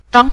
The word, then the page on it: zhang: zhang5